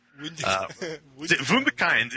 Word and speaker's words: Wunderkind: And I'm still working on Wunderkind, which is how it should be pronounced. Wunderkind, you know? – Wunderkind.